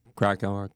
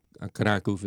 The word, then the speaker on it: Kraków: And she lived in Kraków, in Krakow, Kraków is how they pronounce it in Polish.